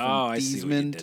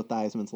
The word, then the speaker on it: Theismann: The pronunciation of Joe Theismann's last name was changed to rhyme with Heisman before his senior year at Notre Dame in 1970.